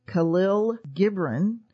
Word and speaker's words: Khalil Gibran: The Prophet by, so I hope I'm going to say this right, Khalil Gibran.